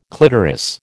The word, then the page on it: clitoris: American English